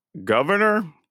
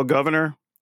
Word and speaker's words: Gouverneur: And I found out about this dude who's named, I guess we pronounce it Governor, but it's spelled G-O-U-V-E-R-N-E-U-R. So like the most French European way to spell governor, I guess.